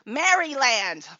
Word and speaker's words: Maryland: The first time I'd ever heard Maryland pronounced that way, because from Ohio, I thought it was pronounced Maryland, because that's how it's spelled. Maryland. Named after Queen Mary. But Danielle informed me that it is pronounced Maryland.